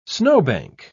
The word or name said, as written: snowbank